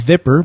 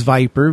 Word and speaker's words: VIPRE: But Viper, pronounced by many as Viper, but I promise you it is Viper, V-I-P-R-E, Sunbelt Software, they're teaming up with Malwarebytes.